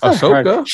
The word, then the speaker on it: Ahsoka: Ahsoka, yeah.